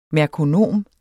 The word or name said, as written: merkonom